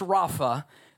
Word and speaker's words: Rapha: The Hebrew word, be still, and I know this is a different word, but it's pronounced Rapha. That's the Hebrew word.